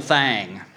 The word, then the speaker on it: thing: Only in Arkansas, it's pronounced little thang. Finger is to fanger as thing is to thang.